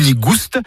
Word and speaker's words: nigouste: Nigou nigouste, avec prononciation du S originel que l'on retrouve dans le provençal goustus, goûteux, et qui a été remplacé depuis par l'accent circonflexe dans notre orthographe moderne.